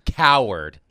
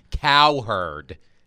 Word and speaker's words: Cowherd: I'd like to apologize to the texter for mispronouncing Colin Cowherd, not Colin Cowherd, like he's a big baby. I'm sorry. Colin Cowherd.